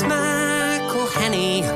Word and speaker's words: McElhenney: It's McElhenney, McElhenney. What ways to massacre and mispronounce it, there are many.